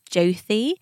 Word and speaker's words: Jyoti: So the name is Jyoti, but it's spelt J-Y-O-T-I.